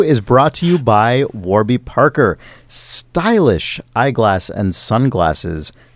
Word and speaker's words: Warby: I always try and say it Warby, so it's clear that it's spelled W-A-R. – Yeah. – Because I sometimes worry if I say Warby, it'll be like W-O-R.